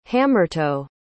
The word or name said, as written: hammertoe